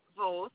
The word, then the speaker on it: Voth: My name is Kathy Voth. It's V as in Victor, O-T-H.